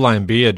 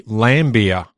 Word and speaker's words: Laimbeer: We had a discussion just a few days ago about the pronunciation of Bill Laimbeer's name, and you've gone and mispronounced it. – I have too. That's right. I forgot all about that. Because of the fact his nickname was Lamb, apparently it's meant to be Laimbeer.